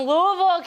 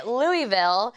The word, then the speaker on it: Louisville: We are here right now with my new friends from Louisville, Kentucky. Yes. I was told not to say Louisville, not to say Louisville, but say it like you're gonna throw up. Louisville is how the natives say it here,